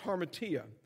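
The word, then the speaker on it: hamartia: The word sin in the Greek means is pronounced harmatia.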